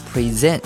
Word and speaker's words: present: 而且一个单词呢可能有好几个意思，比如说 fish 可以表示钓鱼这个动作，也可以表示鱼肉，而 have 可以表示拥有，也可以表示吃饭，比如说 have lunch。而有些单词因为它的发音不同，词性也会发生改变，比如说 present 可以表示礼物，但我们如果把它读成 present，这个时候它就变成了呈现的意思，是动词的词性。